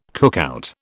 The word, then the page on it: cookout: British English